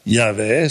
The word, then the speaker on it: Yahweh: J-H-W-H, Yahweh, zo wordt het meestal uitgesproken.